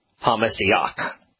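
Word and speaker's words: HaMessiach (המשיח): In fact, even the Hebrew pronunciation, HaMessiach, the Messiach,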